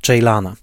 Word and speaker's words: Ceylan: Film z roku 2011 w reżyserii Nuriego Bilge Ceylana. O Ceylanie czy Ceylanie, prawidłowa wymowa to Ceylan.